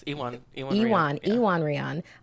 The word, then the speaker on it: Iwan Rheon: She says that there are some, sure, that, of course, they, they've got, I'm going to butcher his name, Iwan Rheon. – Oh, that was close. Ewan Rheon On our show.